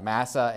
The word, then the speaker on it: Massah: And so in the first case where we saw there, it says that they called the place Meribah and Massah, I believe, right? Am I saying that right? Yeah, Massah and Meribah.